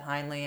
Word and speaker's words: Heinly: So, hey, you're listening to Two Hosts from Delco with Beth Heinle and Maureen Cummings. My last name is spelled H-E-I-N-L-Y, and Maureen's is C-U-M-M-I-N-G-S.